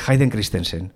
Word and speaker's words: Hayden Christensen: Aparece el personaje de Darth Vader, de Anakin, de Hayden Christensen.